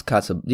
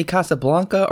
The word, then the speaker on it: Casablanca: Alan, I want to know how do you pronounce Casablanca? Do you say Casablanca or Casablanca? – I've always pronounced it Casablanca, but when I heard Humphrey Bogart say it, he said Casablanca. – Mm-hmm. – So, and I think it's pronounced both ways in the movie.